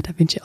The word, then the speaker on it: Da Vinci: Da Vinci. ich weiß, dass er Da Vinci ausgesprochen wird. Ich mache mich nur lustig.